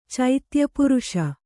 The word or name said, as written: ಚೈತ್ಯಪುರುಷ